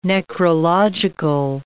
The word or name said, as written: necrological